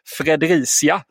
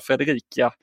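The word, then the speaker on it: Fredericia: vi blev upplavettade direkt igår efter att vi uttalade Fredricia, som man egentligen då ska säga i Danmark, fel. Vi sa Fredrika.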